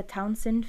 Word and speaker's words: Townshend: So I'm going to pronounce it the Townsend family. It's spelt T-O-W-N-S-H-E-N-D, but I'm pretty sure it's not said Townshend. So,